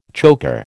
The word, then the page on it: chocker: American English